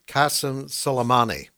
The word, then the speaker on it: Qasem Soleimani: Qasem Soleimani.